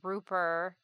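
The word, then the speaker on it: RUBUR: And this song is by the Shanghai, China band Ruper. I think that's how you pronounce it. It's all caps R-U-B-U-R. I've never said the name aloud.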